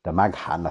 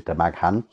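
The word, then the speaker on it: Damghan: And he said, take that to the governor of Damagnan. I think I pronounced that correctly. There's another town further up called Damagnan, Damaghan, I think it is, Damaghan, and he will pay you.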